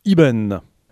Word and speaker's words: Eben: Alors ça s'écrit E-B-E-N, ça se prononce Iben car c'est du breton.